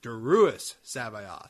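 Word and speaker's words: Joruus C'baoth: It's like, the original Sabaoth was, his first name was pronounced Doris Sabaoth. This one's pronounced Daruus Sabaoth.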